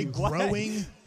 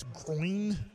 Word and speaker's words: groin: I always say growing, but it's groin, if I'm trying to say it right.